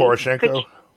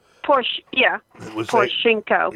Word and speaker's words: Poroshenko: Palenko in power? I think I pronounced his name correctly. – Poroshenko? Poroshenko. – Poroshenko.